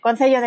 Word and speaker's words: Concello: Concelio, Concello, Concello de Santiago, ¿lo he pronunciado bien? ¿Cómo? ¿Concelo? – Concello? Perfectamente, Concello de Santiago.